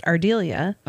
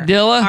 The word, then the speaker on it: Ardelia: I named my daughter after my grandma, and it's pronounced Ardelia. – Uh-huh. – And because we live in Arkansas, anytime we go anywhere, doctor's appointment, whatever. – Ardilla? – Ardelia. Ardelia. – Ardelia. – Ardelia. – I'm like, no, it's not Ardelia. – Ardelia. – Ardelia. – It's not my kid's name. – That's not her name.